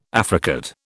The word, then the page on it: affricate: British English